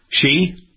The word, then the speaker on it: Xi: how do we say his name, Xi Jinping? Whatever his name is. – Xi. – Xi. Okay. – Xi. – She? – Mm-hmm.